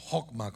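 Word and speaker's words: hokma (חכמה): La palabra para sabiduría en hebreo es chokma, o hokma como se, se pronuncia.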